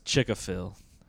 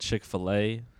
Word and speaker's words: Chick-fil-A: My grandma says Chick-a-fil instead of Chick-fil-A.